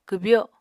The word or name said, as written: kwbyo